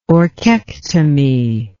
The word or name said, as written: orchectomy